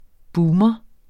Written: boomer

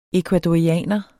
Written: ECUADORIANERE

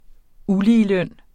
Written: uligeløn

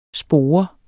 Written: spore

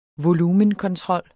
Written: volumenkontrol

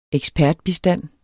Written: ekspertbistand

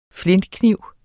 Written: flintekniv

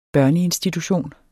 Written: børneinstitution

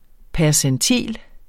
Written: percentil

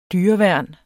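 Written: dyreværn

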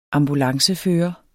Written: ambulancefører

